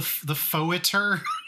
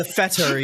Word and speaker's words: Fetor: There's the Fetor, I think it's pronounced, the Foeter. – Oh, the Fetor.